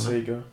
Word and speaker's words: Sager: Spricht man ihn Sager aus? Ja, ne? Sager. Ja, gut, passt.